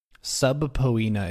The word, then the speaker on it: subpoena: Subpoena is how the word is spelled. Subpoena is how it's pronounced.